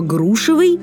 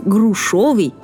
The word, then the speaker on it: грушевый: А вот слово «грушевый» может наоборот запутать, потому что в этом слове буквы «ё» нет и не должно быть. Соответственно, говорить «грушовый» неправильно.